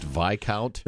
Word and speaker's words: Viscount: You guys, Viscount is it's pronounced Viscount.